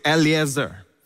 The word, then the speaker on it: Eliezer: Now, Lazarus, in Hebrew, Lazarus, which Jesus would speak Aramaic and Hebrew, it would be Eliezer. Lazarus means helper. Eliezer, of course, means helper in Hebrew. And that's how they say it, Eliezer. So Jesus wouldn't be saying Lazarus, like the Greek would say Lazarus.